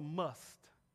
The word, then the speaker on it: musht: must, M-U-S-H-T, the must. I had to look up the pronunciation. I didn't want to get that wrong. It's called the must, right? M-U-S-H-T.